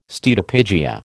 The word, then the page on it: steatopygia: American English